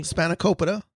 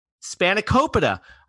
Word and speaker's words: spanakopita: I think it's Spanakopita. Is that right? – Spanakopita. – Spanakopita. I was way off,